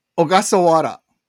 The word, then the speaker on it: Ogasawara: Ogasawara. There's no hard R. – Ogasawara. – Yeah, it's a soft R. It's sort of unique.